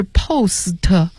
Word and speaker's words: postpone: postpone 这个词呢其实是这样拼的， postpone，也就是这个 T 是不发音的，它原来是 postpone 啊，是两部分组成，但是呢第一个部分里边的 T 不发音，所以呢它这个单词念成 postpone，延期推迟的意思。